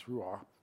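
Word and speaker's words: ruach: It's ruah. I didn't pronounce it right, but it's R-U-A, like C-H or H.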